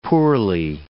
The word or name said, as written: poorly